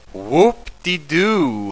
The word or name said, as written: whoop-de-do